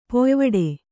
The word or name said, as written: ಪಾಯಿವಟ್ಟ